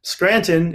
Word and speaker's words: Scranton: And then Scranton is spelled just like the city in Pennsylvania, S-C-R-A-N-T-O-N.